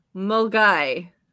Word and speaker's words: Milngavie: And the second one is pronounced Mulgai.